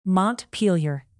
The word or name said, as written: montpelier